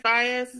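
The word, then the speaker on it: Faiyaz: Brent Fayez is how you say Brent Fayez.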